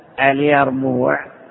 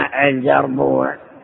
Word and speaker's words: اليربوع: هكذا اسمه اليربوع بالياء، إلا أن العامة قلبوا الياء جيما يقولون الجربوع، وسبب ذلك أن هناك قبائل لا ينطقون بالجيم بل يقلبونها ياء، فسمعهم بعض الآخرين قلدوهم.